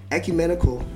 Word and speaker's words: ecumenical: Denominationally, in the Baltimore area for over 15 years, including attending an ecumenical, did I say that right? – You did.